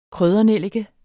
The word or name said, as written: kryddernellike